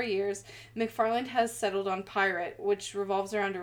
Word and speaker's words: PYRT: This time, he's putting together a service called, it's capital P-Y-R-T, pronounced Pirate, which he calls a virtual, immersive, decentralized reality.